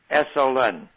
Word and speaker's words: Esalen: Okay, E-S-A-L-E-N, Esalen, E-S-A-L-E-N.